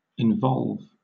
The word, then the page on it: involve: British English